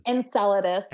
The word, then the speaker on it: Enceladus: What's the Enceladus? Am I saying that right? – Enceladus.